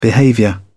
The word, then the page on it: behavior: American English